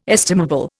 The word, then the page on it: estimable: Indian